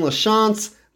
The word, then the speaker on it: LaChance: If you're looking for me, my personals, Brandon LaChance.